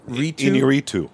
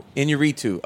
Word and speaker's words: Iñárritu: Alejandro Iñárritu. – Iñárritu.